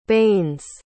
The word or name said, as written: baines